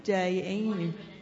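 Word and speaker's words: Dayenu: And so I appreciate, like, all the testimonies, but especially, Bob, what you said about, how do you say that, Dienu? Dienu.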